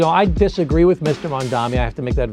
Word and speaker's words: Mamdani: The name is Mamdani. M-A-M-D-A-N-I. You should learn how to say it because we gotta get it right.